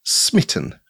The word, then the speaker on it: SMIT I18N: So I have called my project SMIT I18N, which is a bit of a mouthful, but it's intended to be pronounced Smitten because it's for the Love game engine.